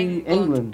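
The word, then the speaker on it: Englund: Como é que pronuncia, gente? É... Robert é England. É England, é. é. – England, é. É isso, é ele mesmo.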